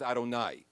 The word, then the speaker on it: Adonai: The God that whose name we pronounce is Adonai. It's spelled Yud-Heh-Vav-Heh.